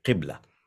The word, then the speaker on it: Qibla: Q-I-B-L-A, Qibla.